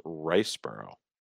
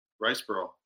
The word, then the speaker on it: Riseborough: I don't know if it's Riseborough or Riseborough. I heard Mark Maron. – Riseborough. I've been saying Riseborough. Yeah. – I heard Mark Maron pronounce it as Riceborough. – Riceborough. Well, he would know.